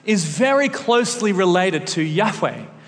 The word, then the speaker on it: Yahweh: And because there's no vowels in Hebrew, there's different thoughts around the world as to exactly how to pronounce this. But one way is Yahweh, Yahweh, Yahweh.